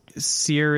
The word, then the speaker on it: cire: I know in French it's cire. Is that close in Latin? Do you know? Cire. – Uh, cire is probably about right.